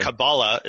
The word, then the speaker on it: Kabbalah: So Kabbalah is the way you'd say it in Hebrew, okay. – but Kabbalah is how you would say it in Yiddish, and thus in Ashkenazi influenced English.